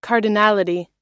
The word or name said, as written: cardinality